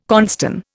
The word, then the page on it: cornstone: Indian